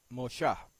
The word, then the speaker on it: Mosha: y se pronuncia Mosha, Mosha-A, Mosha-A, con G al final, sí, con una G al final, ¿okay?